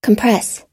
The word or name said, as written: compress